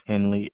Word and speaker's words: Henley: My first name is Cedric, C-E-D-R-I-C. My last name is Henley, H-E-N-L-E-Y.